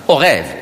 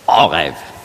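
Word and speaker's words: Orev (עורב): Heute spricht man das nicht mehr aus, sagt man Orev in Israel. Aber Althebräisch, Orev.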